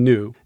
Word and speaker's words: nu: Although, you know, some people were sticklers for the Greek pronunciation and saying it was mi and ni, but in English we tend to say mu and nu. But, you know, if you say nu, that's confusing because it sounds like N-E-W, right?